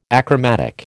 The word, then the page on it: achromatic: American English